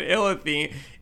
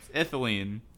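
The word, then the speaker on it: Ithlinne: Illithine? Can you say that? – No, no, that's the wrong way. – Please repeat, sir. – Ithiline. – Ithiline. Ithiline. Did I say it right that time? – Yes.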